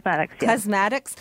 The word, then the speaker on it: Klezmatics: You can book tickets for the Klezmatics, is that how you say it? – Klezmatics, yeah.